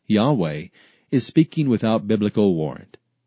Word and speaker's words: Yahweh: Ancient Hebrew did not have vowels, so the exact pronunciation of YHWH is uncertain. The vast majority of Hebrew and Christian scholars believe the name to be pronounced Yahweh, with Jehovah being the second most popular possibility.